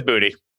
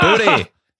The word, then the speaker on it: Boutte: Yeah, he says Boutte. – Oh! – Boutte. – Booty.